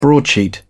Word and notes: broadsheet: British English

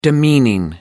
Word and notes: demeaning: American English

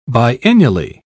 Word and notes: biennially: British English

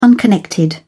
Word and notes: unconnected: British English

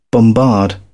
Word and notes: bombard: British English

